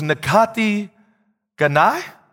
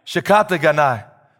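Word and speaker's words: Shikata Ganai (仕方がない): I think it was Nakati Ganai. I hope I'm saying that right, Brother Paul. Is it? Shikata Ganai. I hope I said it. Is that better? All right, that was better.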